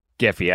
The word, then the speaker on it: Giphy: For our second story, UK regulators are demanding Facebook parent company Meta to sell Giphy, and we are saying Giphy, and that is the correct way to pronounce it.